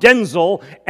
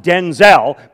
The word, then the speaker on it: Denzel: Denzel, and they both would answer, "Yes," that his mama, in frustration, told him, "Son, your name is now pronounced Denzel, because I'm sick of all the confusion."